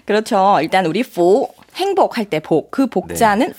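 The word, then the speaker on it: 푸 (福): 에, 푸. F 사운드. – 네. F 사운드. 푸.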